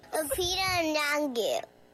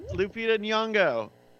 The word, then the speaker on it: Lupita Nyong'o: Lupita Nyong'o. – I heard first. – Lupita Nyong'o. – Lupita Nyong'o.